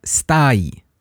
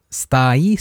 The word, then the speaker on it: stai: So if you just try to repeat these three words in the beginning, ciao, good, and then come, good, and then stai, like two syllables, stai.